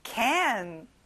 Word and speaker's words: can: 자, and 발음이에요. 입을 크게 벌려보세요. can.